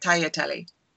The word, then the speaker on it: tagliatelle: You know, the G is pronounced more like a Y and you have to say it tagliatelle.